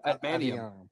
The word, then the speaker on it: adamantium: adamantium. How do you say that? – Oh, adamantium.